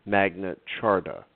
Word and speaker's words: Magna Carta: and sign the Magna Charta, Magna Carta, we pronounce it, the French way, the Brits, Magna Charta, and they're right, Magna Charta not the French way.